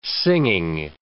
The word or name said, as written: singing